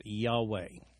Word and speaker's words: Yahweh: Incidentally, when the Lord is written in all capital letters in the Old Testament, that's the indicator signifying a translation of the Hebrew word Jehovah, or usually or oftentimes pronounced Yahweh.